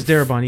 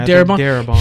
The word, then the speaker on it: Darabont: The movie was directed by Frank Darabont. Am I pronounced that right? – I think it's Darabont. Yeah, Darabont.